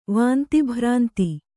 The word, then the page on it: ವಾಂತಿಭ್ರಾಂತಿ: Kannada